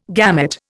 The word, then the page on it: gamut: Indian